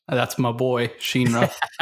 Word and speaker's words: Shinra: And so whenever his name is pronounced, the director specifically wants a flipped R on Ra as opposed to Shinra.